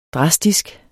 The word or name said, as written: drastisk